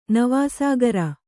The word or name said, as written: ನವಾಸಾಗರ